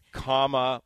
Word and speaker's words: Kamala: it all off, uh, Camilla. – Kamala. – Kamala. – Yeah. Yes, In case you're wondering you. how to pronounce it. – Comma. – Comma. – Yeah. Like the comma.